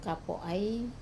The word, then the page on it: kapo ai: wamesa